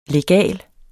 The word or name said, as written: LEGALE